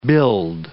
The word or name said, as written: billed